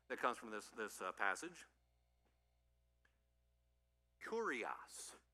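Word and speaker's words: kurios (κύριος): That's how that's the English letters, but the way you pronounce it is kurios. So say that with me, please. Kurios. Kurios. good way to remember it. It fits our English curious, but you don't have the ear, you just have the er with this one.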